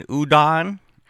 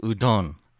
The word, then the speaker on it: udon: And the Caucasian server, young woman, said, oh, you mean udon? And I had to correct her in a Japanese restaurant. No, it's pronounced udon.